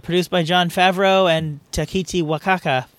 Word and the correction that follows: Taika Waititi: Fuck, I know I said his name wrong. The guy who directed Thor 3. – Wow, Taika Waititi.